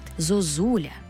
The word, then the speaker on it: зозуля: Наведу приклад. Зозуля. Як падає наголос? Зозуля. Ми чітко чуємо другу «у». А от першу «у» ми, як і іноземці, не будемо знати, як писати. Зозуля чи, може, зузуля.